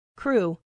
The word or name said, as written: crew